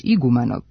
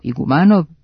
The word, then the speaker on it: Igumanov: te je prvobitno bio Sima Andrejević Igumanov, sa akcentom na prvom slogu, kako glasi i pridev od imenice Iguman. Po analogiji, najčešće sa ruskim prezimenima, akcenat se ustalio kao Igumanov, pa otud i težnja kao obliku Igumanovljev.